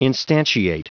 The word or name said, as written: instantiate